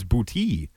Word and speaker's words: Boute: How do you say your last name?" He goes, Booty. It's Booty, just Booty.